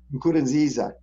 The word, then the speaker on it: Nkurunziza: But on the COVID issue, in early June, we got news that President Nkurunziza, oh boy, can you pronounce that for me? – Nkurunziza died.